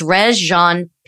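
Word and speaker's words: Regé-Jean: So it's Jane Page, the guy from Bridgerton? – Mixing him up with Rajon Rondo. – It's R-E-G-E, but it's I think it's Rajon Page.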